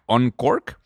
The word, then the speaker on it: Unqork: De hecho, la número tres que me gustó, OnCork, es, esto se escribe U-N-Q-O-R-K, OnCork.